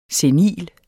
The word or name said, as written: senil